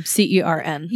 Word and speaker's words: CERN: is the theory that CERN, I think is how it's pronounced. – It's like an acronym, CERN.